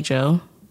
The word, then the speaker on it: Djo: But I'm going to go with the song End of Beginning by Joe. – Okay. – I'm pronouncing it weird because it's spelled D-J-O. – Okay. I would say Joe.